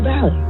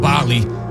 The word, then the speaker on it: Bali: I want to go on a trip to Bali. – Well, first of all, it's pronounced Bali.